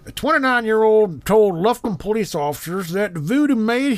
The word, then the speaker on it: Lufkin: Is that how it's pronounced? Lufkin? Lufkin? Sure. – Lufkin.